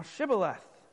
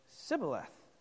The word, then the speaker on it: Shibboleth: And if he say nay or no, and he say unto him, "Say now Shibboleth." And he said, "Sibboleth." They could not frame to pronounce it right.